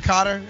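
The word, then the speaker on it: Koetter: How do you pronounce it? Cotter? That's right.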